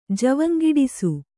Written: ಜವಂಗಿಡಿಸು

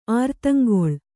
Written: ಅರೆದಿಂಗಳು

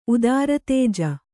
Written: ಉದಾರತೇಜ

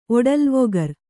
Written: ಒಡಲ್ವೊಗರ್

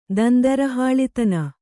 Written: ದಂದರಹಾಳಿತನ

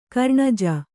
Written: ಕರ್ಣಜ

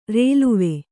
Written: ರೈಲ್ವೆ

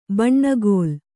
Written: ಬೆಣ್ಣೆಗಲ್ಲು

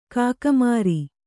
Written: ಕಾಗೆಮೋರೆ

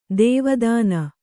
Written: ಧವಾದಿನಿ

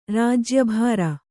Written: ರಾಜ್ಯಭಾರ

